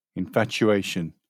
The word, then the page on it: infatuation: British English